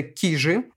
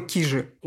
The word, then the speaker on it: Кижи: Во-первых, замечательно, что вы произносите э, с ударением на первый слог. Это магическое слово. Вот это уже делает вас почти местным жителем, потому что туристы, как правило, говорят кижи, и оба произношения правильные, но, конечно, местные говорят кижи.